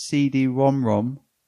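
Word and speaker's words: CD-ROM²: The CD-ROM squared, pronounced CD-ROM-ROM.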